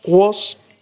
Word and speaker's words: was: Hay que tener mucho cuidado con was, porque esa se escribe W-A-S, pero esa A no es una A, es más bien como una O. Está entre la A y la O, pero ante la duda, pronunciad una O.